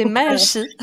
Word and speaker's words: Maachi: J'espère que je n'écorche pas ton, nom à chaque fois. C'est Machi. – Machi, pardon.